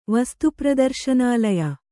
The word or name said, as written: ವಸ್ತುಪ್ರದರ್ಶನಾಲಯ